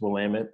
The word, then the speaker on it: Willamette: And then, you know, this school up north called Willamette, which is Willamette, but when you read it. – Willamette, yeah. And I grew up in Portland, so I heard that a ton. A lot of people mispronouncing that name.